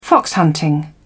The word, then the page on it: fox hunting: British English